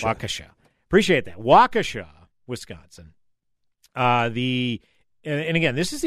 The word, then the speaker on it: Waukesha: 48 hours later, this incident happens in Waukesha, Wisconsin. This was the initial AP report. Yeah, go ahead, John. – Just want to clarify, Waukesha. I've got family over there. It is Waukesha.